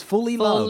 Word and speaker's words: Fullilove: I mean, how would you say this name? F-U-L-L-I-L-O-V-E. That's fully love.